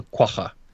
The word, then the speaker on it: quagga: and it's actually called a quagga.